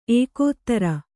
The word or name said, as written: ಏಕೋದರ